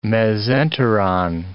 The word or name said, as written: mesenteron